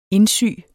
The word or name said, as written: indsy